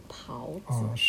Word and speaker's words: 狍子: By the way，刚才那个字你发音是对的，是读狍子，是吗？